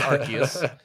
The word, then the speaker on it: Arceus: Oh, it is pronounced Arceus.